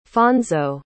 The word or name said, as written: fonso